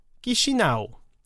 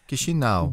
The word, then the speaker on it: Chișinău: Donc, et la capitale, c'est, ça s'écrit Chișinău, mais c'est, et puis là, je suis allé écouter là, ouais. Plusieurs versions, c'est Chișinău. – Chișinău. – Oui, c'est ça. Chișinău. – Chișinău.